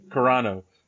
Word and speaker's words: Carano: Carano from Mandalorian, of course.